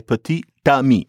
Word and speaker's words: petit ami: Put petit and ami together, you have to say petit ami. And all of a sudden, the T that's only in the spelling, you have to pronounce it.